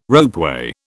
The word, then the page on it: ropeway: British English